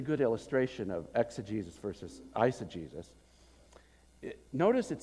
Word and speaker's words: eisegesis: Now, the opposite, the thing we abhor, is pronounced in English, eisegesis, the Greek prefix eis, into, which means I read into the Bible what I want to find there, right?